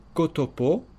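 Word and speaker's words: ktp: Et puis ces trois petites lettres que l'on prononce en espéranto, kotopo, pour dire...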